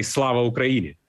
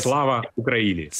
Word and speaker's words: Slava Ukraini: It's better to say Slava Ukraini. – Say again? – Glory to Ukraine. Yes. Slava Ukraini. – Slava Ukrainia.